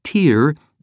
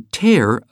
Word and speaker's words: tear: How are you supposed to know that when you cry a tear, it rhymes with beer, but when you tear a page, it rhymes with bear?